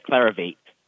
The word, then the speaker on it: Clarivate: He is in Hayward looking at CLVT, which is Clarivate. Is that how you say it? – Yes, Clarivate.